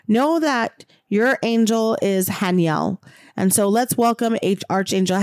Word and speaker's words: Haniel: The next one, and I never said this right, so it's Haniel. H-A-N-I-E-L.